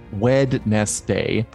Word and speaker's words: Wednesday: I say it because Wednesday is spelled W-E-D-N-E-S-D-A-Y. It's spelled Wednesday, probably because entomologically it's written in weddings, but it's pronounced Wednesday. It makes no sense.